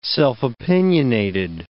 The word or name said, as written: self-opinionated